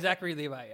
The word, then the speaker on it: Zachary Levi: Yeah, Zachary Levi.